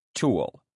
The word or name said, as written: tool